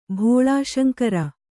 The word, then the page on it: ಭೋಳಾಶಂಕರ: Kannada